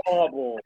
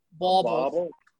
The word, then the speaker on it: bauble: Bauble. How do you say it in American? – Ornament. – Bauble. – Yeah, like ball. But before you get to the L part, you add a bull.